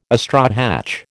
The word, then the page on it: astrohatch: American English